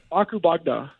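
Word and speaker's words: Ako Bog da: Wait, can you say that? – Akubagda. – Akubagda? – Yeah, Akubagda. – We want to make sure we're saying it correctly.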